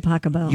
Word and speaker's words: Pachelbel: Canon D by Pasquale. – By Pachelbel. – You pronounce it Pasquale? – No.